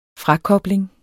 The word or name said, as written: frakobling